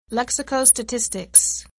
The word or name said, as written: lexicostatistics